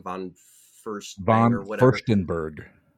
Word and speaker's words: von Furstenberg: What signature piece of clothing by Diane von Furstenberg celebrated its 40th anniversary in 2014? – 20th anniversary in 2014. That would put it in 74. I recognize the name von Furstenstein. – Von Furstenberg.